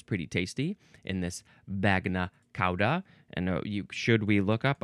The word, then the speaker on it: bagna cauda: Ah, it is pronounced bagna cauda, which means hot sauce. Hot sauce coming through. So yes, bagna cauda. That's how you officially pronounce it.